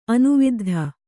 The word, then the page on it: ಅನುವಿದ್ಧ: anuviddha